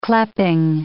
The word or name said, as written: cleping